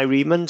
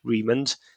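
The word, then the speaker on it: Remond: So you can find me on LinkedIn under Guy Riemann.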